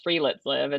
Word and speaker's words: friluftsliv: It's called Free Let's Live.